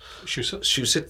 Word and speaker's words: Shusett: Shusett, thank you.